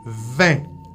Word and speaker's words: vingt: Ok, la se escribe B-I-V. – No. V. – En español. – Ah. – V. V-I-N-G-T. Pero en francés, les consonnes qui sont... Que son atrás de las palabras no se pronuncian.